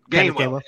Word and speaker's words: Gainwell: Gainwell.